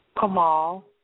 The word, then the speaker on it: Kamal: Kamal. – How do you spell that? – K-A-M-A-L. – K-A-M-A-L.